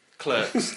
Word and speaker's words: Clerks: And for me, Ben will want me to pronounce it Clerks. – Correct. We're not Americans. – Clerks.